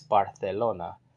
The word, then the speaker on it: Barcelona: So that's why you say Barcelona instead of Barcelona. It's Barcelona.